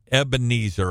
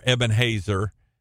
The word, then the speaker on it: Ebenezer: So several weeks ago, I preached a message on Ebenezer, or Ebenhazer is a better pronunciation of it.